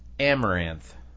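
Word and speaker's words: Amouranth: Just say Amareth.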